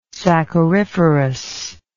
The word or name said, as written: sacchariferous